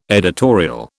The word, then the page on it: editorial: British English